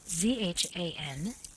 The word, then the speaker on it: Zanduja: The proper pronunciation of this is as if it were spelled Zhanduya.